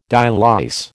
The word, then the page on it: dialyse: American English